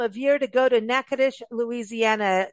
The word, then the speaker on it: Natchitoches: Natchitoches is the oldest settlement in the state of Louisiana, and I'm going to spell it out. N-A-T-C-H-I-T-O-C-H-E-S, Natchitoches. It doesn't sound how it's spelled, but you've got to go there. Don't let that spelling get you.